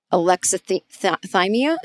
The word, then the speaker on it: alexithymia: And some men are raised in such a way that they get a mild version of a psychological condition called alexithymia.